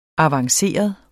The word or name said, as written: avanceret